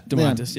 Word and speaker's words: Domantas: Well, I was thinking Domantas at first, and I might be saying his name wrong, but is it Domantas? – Yeah, Domantas.